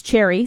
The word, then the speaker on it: Chxrry: Her name's Cherry, spelled with an X, so it's like C-H-X-R-R-Y.